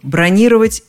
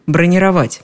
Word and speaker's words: бронировать: Если правильность постановки ударения в слове «бронировать» затрудняет, то попробуйте связать его с другим, более легким словом «резервировать». Бронировал – резервировал. Бронируй – резервируй.